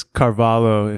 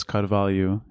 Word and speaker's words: Carvalho: It's Carvalho if you say it in English, but it's Carvalho in Portuguese.